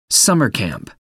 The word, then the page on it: summer camp: American English